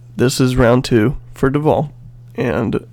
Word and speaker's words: Duvel: But it's a Belgian golden ale, pronounced Duvel, D-U-V-E-L. However that makes sense, I don't know,